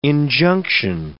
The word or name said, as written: injunction